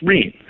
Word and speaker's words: Rene: Now, it's spelled Rene, but according to the family, it's pronounced Rene.